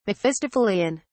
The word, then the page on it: mephistophelean: British English